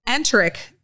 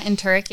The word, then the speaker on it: enteric: At the same time, the enteric nervous systems, did I say that right? – Yeah, enteric, yeah.